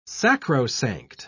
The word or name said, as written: sacrosanct